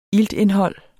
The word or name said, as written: iltindhold